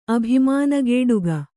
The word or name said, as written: ಅಭಿಮಾನಗೇಡುಗ